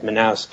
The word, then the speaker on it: Manaus: But we don't really offer free shipping because, you know, getting up to Manaus, which is how you say it, Manaus, you know, is expensive.